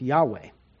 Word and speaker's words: Yahweh: His memorial name, this is my name forever, and this is my memorial name to all generations, is those four letters, YHWH, that we usually pronounce Yahweh.